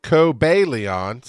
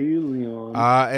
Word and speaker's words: Cobalion: So it is pronounced, according to my book, Cobalion. So I did say it basically right. Cobalion.